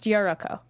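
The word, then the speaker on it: Yolko: The way they pronounce it, it's Yoruko, but it's spelled like yolk and then... – Yeah, but it's pronounced Yoruko.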